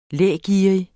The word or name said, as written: lægerrig